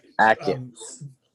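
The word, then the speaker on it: Achatz: One of the episodes I really did like was when they had, and I'm gonna get his last name wrong, Grant, is it Achatch? Achatch. – Ackits, like a K.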